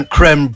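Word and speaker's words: crème: It's spelt as in creme brulee. So I say creme.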